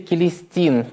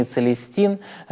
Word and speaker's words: Целестин: Святитель Келестин, папа Римский, или в латинском произношении Целестин, родился в IV веке, а архипасторское его служение выпало на 20-е годы V века.